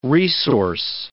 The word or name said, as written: resource